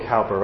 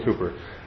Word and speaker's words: Cowper: It's spelled Cowper, right? C-O-W, but William Cowper.